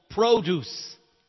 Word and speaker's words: produce: Even in the supermarket, it's called produce. It's the same word, but pronounced differently because it's the noun.